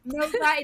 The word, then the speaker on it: Nova: Like, Nova, it's two syllables. That's as much as we can manage when we're on track and yelling your name to get your attention. Nova it is.